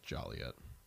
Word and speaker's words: Joliet: It's every story just takes place in Joliet. Weird. Oh, well. – Hmm. – If I was to tell stories, you know. – It's pronounced Joliet.